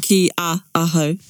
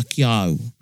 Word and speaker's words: ki aho: Because that'll be weird, ki a aho. – With o, you put the a in, so there's a lot, it sounded in the pronunciation.